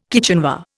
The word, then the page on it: kitchenware: Indian